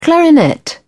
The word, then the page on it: clarinet: British English